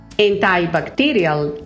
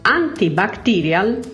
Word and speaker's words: antibacterial: Ricordate che l'aggettivo antibatterico ha due pronunce, antibacterial in inglese americano e antibacterial in inglese britannico.